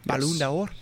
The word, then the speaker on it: Ballon d'Or: Ballon d'Or. Ballon d'Or.